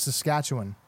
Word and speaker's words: Saskatchewan: They will tell you that it is Saskatchewan.